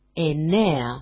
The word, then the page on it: enea: ene-a